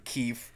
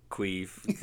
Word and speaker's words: Keefe: And he's got his friend Keefe. – Yeah. Queef. That in the second season, John Goodman has trouble pronouncing his name, but he keeps saying it basically like Queef to Keith or with a Q.